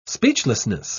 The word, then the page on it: speechlessness: British English